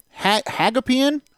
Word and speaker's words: Hagopian: And in the A-class wing, Jake Hagopian. You got to put the emphasis on the O.